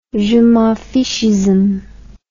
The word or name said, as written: je-m'en-fichisme